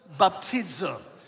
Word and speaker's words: baptizō: Would you please say that word? Baptizō. And yes, I am pronouncing it correctly.